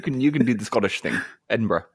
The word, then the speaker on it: Edinburgh: Edinburgh. – Did I say that right? Edinburgh. Is it Edinburgh? Edinburgh. That's what I'm going to say. – You just got to compress that sound.